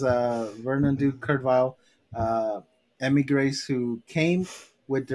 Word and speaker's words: Weill: This Kurt is, it's spelled W-E-I-L-L. – Oh, okay. – Yeah, but it's still pronounced Vile.